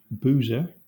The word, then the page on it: boozer: /ˈbuː.zə(ɹ)/